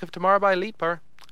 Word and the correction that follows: Lightbur: That was Leet. Sorry, actually, the pronunciation is right here in front of me. Lightbur. That was Lightbur with Think of Tomorrow.